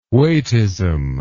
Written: weightism

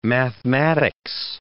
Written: mathematics

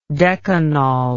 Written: decanol

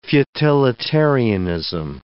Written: futilitarianism

